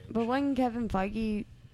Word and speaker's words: Feige: Oh, Feige. I'm sorry. Okay. – Feige. – Sorry.